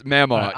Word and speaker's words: Mamaw: It's Mamaw. Right, yeah, it's M-A.